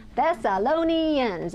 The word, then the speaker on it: Thessalonians: Thessalonians. It's five syllables and it's a mouthful, and I stumble over it often.